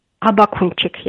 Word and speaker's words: Abakunchik: And police cracked down quite hard on these demonstrations, arresting pensioners, journalists, including your colleague, Halina, I hope I don't mispronounce her name, Abakunchik. – Abakunchik, yes.